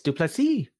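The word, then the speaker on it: du Plessis: Duplessis, if I'm saying that correctly, Duplessis, Duplessis, almost like uh, Dupree in UFC. It's Duplessis. He that's his pronunciation.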